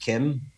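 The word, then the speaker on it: Keem: Do you know, like Keane Bay, as people pronounce it, but the locals pronounce it Kim.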